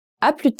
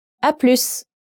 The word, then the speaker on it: à plus: With friends, French people tend to shorten à plus tard to à plus. In this case, you must pronounce the S.